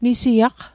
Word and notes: nisiaq: Mi'gmaq

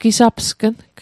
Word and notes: gisapsgng: Mi'gmaq

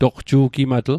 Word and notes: toqju'gimatl: Mi'gmaq

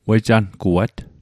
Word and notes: wejanguat: Mi'gmaq

